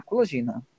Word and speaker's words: Kulagina: Colagina se escreve K-U-L-A-G-I-N-A. Colagina.